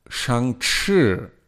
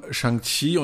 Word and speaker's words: Shang-Chi: Und wenn ich den Filmtitel lese, würde ich sagen, es heißt Shang-Chi und nicht Shang-Chi, weil es ist am Ende C-H-I und nicht Q-I.